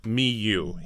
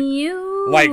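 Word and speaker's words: Myuu: It is pronounced Miu. He says it on his website. – Miu. – Like me and you.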